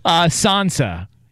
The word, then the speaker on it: Sansa: Sansa.